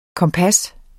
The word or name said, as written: kompas